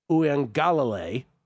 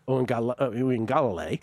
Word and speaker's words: Uiagalelei: How is it that we, as sports broadcasters, we can say, we figured out a way to say DJ Uiagalelei, right? Uiagalelei.